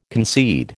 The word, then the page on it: concede: American English